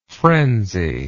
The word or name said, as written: frenzily